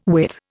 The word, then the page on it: withe: Indian